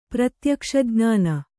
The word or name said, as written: ಪ್ರತ್ಯಕ್ಷಜ್ಞಾನ